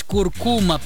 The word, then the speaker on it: куркума: Несмотря на то, что большинство произносит данное слово с ударением на последний слог, акцент в нем все же стоит на втором. Правильно говорить куркума, представляете?